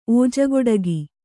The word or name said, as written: ಓಜಗೊಡಗಿ